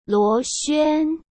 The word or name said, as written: Luo Xuan